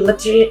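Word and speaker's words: Lotería: Loteria? – Yes, thank you, Loteria.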